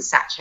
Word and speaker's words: saj: I learned to make flatbreads on what they call a Turkish satch, which is like an upside down sky dish. S-A-J, but I think you pronounce it satch.